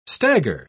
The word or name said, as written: stagger